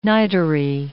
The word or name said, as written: nitery